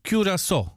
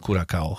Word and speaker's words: Curaçao: Po naszemu to będzie Curaçao. Dokładnie tak to się powinno wymawiać. Curaçao, a nie tam Curacao. Chociaż Curacao jakoś tak ładniej brzmi. Teraz